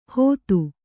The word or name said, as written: ಹೊತ್ತು